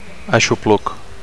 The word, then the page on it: aixopluc: Catalan